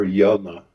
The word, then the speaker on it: Yonah: So we are continuing in Jonah, or Yonah, as the word is properly pronounced.